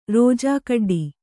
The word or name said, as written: ರೋಜಾಕಡ್ಡಿ